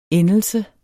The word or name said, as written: Endelser